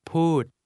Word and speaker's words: พูด: พูดพูด Speak is pronounced with a falling tone.